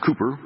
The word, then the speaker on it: Cowper: William Cooper, that's how it's pronounced, Cooper, although it looks like Cowper, said, "God moves in mysterious ways, his wonders to perform.